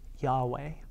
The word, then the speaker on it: Yahweh: often pronounced Yahweh.